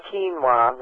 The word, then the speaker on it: quinoa: It's spelled Q-U-I-N-O-A. It's pronounced quinoa, like K-E-E-N-W-A, quinoa. – Q-U-I-N-O-A. – Q-U-I-N-O-A.